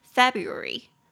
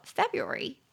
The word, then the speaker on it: February: 就是 drop drop the r sound，他们会把那个音给去掉，所以会变成 February February。